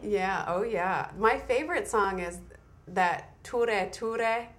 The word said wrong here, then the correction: Tura: I don't know if I'm pronouncing it correctly. – Tura. – La Tura. Yeah, oh gosh.